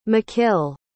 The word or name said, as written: mckile